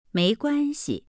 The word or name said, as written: meiguanxi